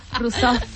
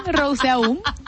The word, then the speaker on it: Rousseau: Rousseau. Por ahí. – Usted piensa en Dilma Rousseff y dice Rousseau. Y ya. – Rousseff, no es igual. Rousseau. Eso es válido, ¿vale? – Es válido el español, no, es verdad.